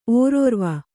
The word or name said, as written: ಓರೋರ್ವ